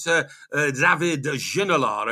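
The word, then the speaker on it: David Ginola: We talked about David Ginolard, OK? David, that is the French expression, the French pronunciation for David.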